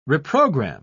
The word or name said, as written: reprogram